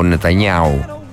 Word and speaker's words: Νετανιάχου: Ο Ρετζέπ Ταϊπ Ερντοάν ζήτησε, μάλλον οι υπηρεσίες, οι δικαστικές υπηρεσίες της Τουρκίας ζήτησαν να συλληφθεί ο Νετανιάχου, ο Νετανιάου που λένε και οι Ισραηλίτες και οι Ισραηλινοί και οι Εβραίοι, και όσοι ξέρουν πώς προφέρεται το όνομα του Πρωθυπουργού του Ισραήλ, λέγεται Νετανιάου.